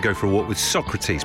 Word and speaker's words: Socrates: It is pronounced Socrates. Thank you.